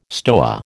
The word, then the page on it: stoa: American English